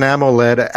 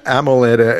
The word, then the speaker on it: AMOLED: The screen is at what? OELD? Is that what it is? Am I saying it right? – It's an AMOLED, active matrix, organic LED. – And, okay. We'll go with that instead. – Yeah. AMOLED. And the glass window.